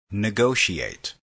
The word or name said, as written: negotiate